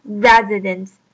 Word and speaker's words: residents: 它的重音是 re, 因此我们读作 residents, 并且在这边它的 s 的音其实是发作 z 的 z 的音。